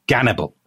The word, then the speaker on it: Gannibal: Now, the Russians pronounce that Hannibal, hence that's why he's called Gannibal, as it were.